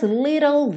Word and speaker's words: little: Você pega o T e transforma em rarara. Fez o rarara, little, L, língua no sol da boca, ok? E o E? O E não é pronunciado, como quase sempre, certo? Então, little, little. And though there's little variation, it nullifies the night.